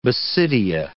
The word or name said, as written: basidia